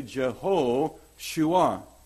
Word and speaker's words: Jehoshua: And if you said Joshua as it would be pronounced in its longer form in Hebrew, you would say Jehoshua.